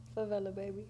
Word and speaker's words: Favelababy: Favelababy. – Favelababy? – Mm-hmm. You've been mispronouncing this. – I thought it was Fave.